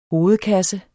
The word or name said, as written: hovedkasse